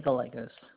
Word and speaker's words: Gallegos: Renee Gallegos.